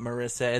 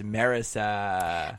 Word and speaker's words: Marisa: Am I saying her name correctly? I feel like Kermit said it interestingly like that. It's not Marisa. It's Marisa.